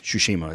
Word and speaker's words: Tsushima: Tsushima is how they pronounce it in the game.